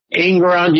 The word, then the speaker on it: Engoron: Well, Engoron, is that how you say his name? – Engoron, yeah.